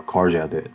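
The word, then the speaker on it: Korçë: I think it's K-O-R-G-A, Korsha, or Korsha, they pronounce it.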